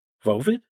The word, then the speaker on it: Voivod: And we mentioned, sort of in a roundabout way, he was in a band from Quebec, Vovid. I don't know if I'm saying that right. V-O-I-V-O-D. I'm going to go with Vovid. – V-O-I-V-O-D? – V-O-I-V-O-D.